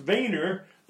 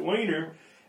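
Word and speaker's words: Weiner: It should have been pronounced Wiener, but I guess over time they called it Wiener.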